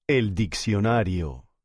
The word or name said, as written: el diccionario